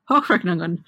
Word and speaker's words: Hochrechnungen: How accurate are the Hochrechnungen? Listeners, I apologize. I have not lived in Germany for 8 years now. – that was a perfectly decent pronunciation.